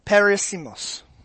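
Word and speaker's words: peirasmos: The word trials in Greek is perissimos. Perissimos. I will spell it for you. It's P-E-I-R-A-S-M-O-S.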